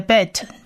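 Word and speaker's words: bet (בית): 这 behor 呢有三个字母，第一个字母是 bet，bet，希伯来文的 bet，它在发音上念 b 的音。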